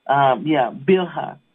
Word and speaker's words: Bilhah: She had to watch Leah and her handmaid, and then she had to watch, I think her name was Bilhah. Now I want to make sure that I'm saying that right. Yeah, that she took her handmaid. Bilhah, amen, Bilhah.